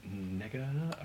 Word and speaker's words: Negga: Ruth Negga, I think I'm saying that right. – Correct. N-E-G-G-A. – Yeah, you don't pronounce the R in the end, as I've been told my whole life.